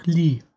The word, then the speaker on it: Lei: Wie wird der Name ausgesprochen? – Laut Hörbuch Lee.